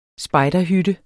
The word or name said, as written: spejderhytte